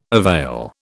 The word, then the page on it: avail: British English